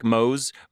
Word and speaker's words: Mohs: It's spelled M-O-H. Hardness scale, like Mohs, but like in my mind when I hear it, I think Mohs from the Three Stooges, and I'm like, oh, the Mohs hardness scale.